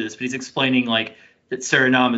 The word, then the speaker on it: Suriname: He's an international player from, and I'm going to totally butcher this country's name, but it's like Suriname or Suriname. I'm not sure how it's pronounced. – Suriname. – But it's in Suriname. – Thank you.